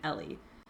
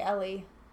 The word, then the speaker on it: Eli: Oh, I just looked at the IMDb, and it said, how is, Eli pronounced, and it said in the movie Eli is pronounced as one might pronounce the name Ellie.